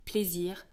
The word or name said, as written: plaisir